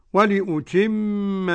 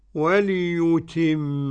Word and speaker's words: ولأتم: ولأتم نعمتي عليكم. كلمة ولأتم، ولأتم إذا وقف حمزة، ولأتم، ولأتم، وليتم. إذا وقف حمزة ضرورة أو اختبارا يقول ولأتم، ولهتم، وليتم.